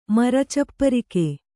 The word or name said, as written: ಮರಚಪ್ಪರಿಕೆ